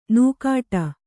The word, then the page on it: ನುಗ್ಗಾಟ: nuggāṭa